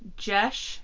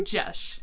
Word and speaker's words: Gesche: Yeah, her name's spelled GESCHE. And I literally searched for, like, 5-10 minutes on, like, how to pronounce this. And, like, went and, like, heard different pronunciations. I was like, this is the easiest one.